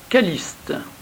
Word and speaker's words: Calliste: Le 14 octobre, nous fêtons Saint Calliste ou Calixte. Calliste est la prononciation ancienne et Calixte la prononciation plus récente.